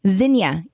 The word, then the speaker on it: Zinnia: Did you say Zinnia? – Zinnia. Yeah. – Zinnia. – Z-I-N-N-I-A.